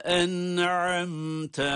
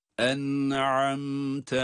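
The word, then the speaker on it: En'amte (أَنْعَمْتَ): Sıratallezine en'amte aleyhim. En'amte. Hem nunun okunuşunda hem mimin okunuşunda cezimli olmalarına rağmen, hatta izhar olmalarına rağmen hunne sesini duyurmalıyız. Bakınız. En'amte aleyhim.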